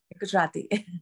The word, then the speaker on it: Gujarati: You're very specific in typing a lot of this cooking as Gujarati cooking. I keep hoping I'm pronouncing this right. If I'm not, I apologize. – Gujarati.